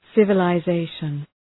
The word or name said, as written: civilization